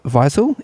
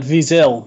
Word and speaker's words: Wiesel: Eli Wiesel, is that his name? I'm not literary enough to know how to pronounce his name. You have to I would try it, Wiesel. – Wiesel, okay.